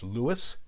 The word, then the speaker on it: Louis: For our information, Armstrong pronounced his first name as Louis, as shall we.